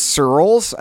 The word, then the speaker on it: Searls: Well, first of all, if you don't know me or follow me on the internets already, my last name is Searls, and my wife, who is a teacher on the first day of class every year, will explain that it's spelled like the word pearls, like in the ocean, except with an S instead of a P.